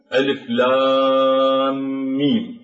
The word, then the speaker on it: الم: فكيف ننطقها؟ ننطقها ألف لام ميم، فندغم بين الميم والميم، وهذا الألف لام ميم هكذا ننطقها ألف لام ميم. فالميم هنا أدغمت هذه الميم من لام مع الميم من ميم، فالألف هنا جاء بعدها هذان الميمان المدغمان ووقعت في الوسط، فنمدها هنا. هذا أيضا مد لازم حرفي ومشدد، لأنه هذان الحرفان قد شددا، أدغما وشددا، ألف لام ميم.